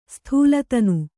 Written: ಸ್ಥೂಲತನು

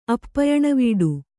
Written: ಅಪ್ಪಯಣವೀಡು

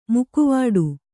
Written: ಮೊಕವಾಡ

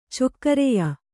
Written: ಚೊಕ್ಕರೆಯ

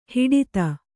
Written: ಹಟಾತ್ತು

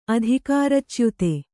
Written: ಅಧಿಕಾರಚ್ಯುತಿ

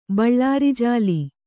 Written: ಬಳ್ಳಾರಿಜಾಲಿ

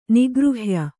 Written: ನಿಗೃಹ್ಯ

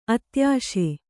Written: ಅತ್ಯಾಶೆ

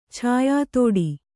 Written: ಛಾಯಾತೋಡಿ

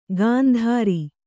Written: ಕಂತ್ರಿ

